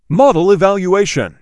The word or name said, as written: Model evaluation